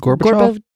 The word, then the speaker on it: Gorbachev: It said that the Soviets strongly opposed the SDI, and talks between Reagan and, I'm not sure how to pronounce this, Gorbachev. Yep. I know Gorbachev. He passed away, I think it was last year, and I got real sad. – I don't know who he is. – Gorbachev.